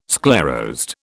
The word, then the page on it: sclerosed: British English